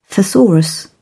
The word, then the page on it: thesaurus: British English